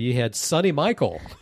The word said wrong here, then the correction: Michel: Right. Sonny Michel was my pick.